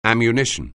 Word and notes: ammunition: anglais

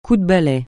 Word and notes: coup de balai: francais

